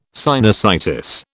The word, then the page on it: sinusitis: British English